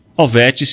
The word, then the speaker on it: Hovet: Ovet se escreve H-O-V-E-T.